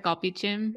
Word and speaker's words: galbijjim: Yeah, like the galbijjim. Did I say that right? – Yes, you said it very well.